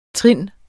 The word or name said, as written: trind